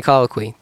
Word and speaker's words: colloquy: Can you colloquy? That's how you say it. Colloquy.